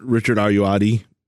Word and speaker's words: Richard Ayoade: Yeah, and Richard Ayoade. Yep. I think that's how you say it. Something like that. – Pretty damn close to how he says it.